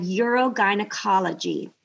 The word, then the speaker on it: urogynecology: She is a trailblazer as the first female in the United States to become a board-certified urogynecology.